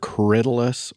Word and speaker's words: Corydalis: The first of these is a perennial called Corydalis, or sometimes it's referred to as fumitory. It's spelled C-O-R-Y-D-A-L-I-S, Corydalis.